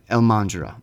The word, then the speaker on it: Elmandjra: Elmandjra, I believe is how you say it.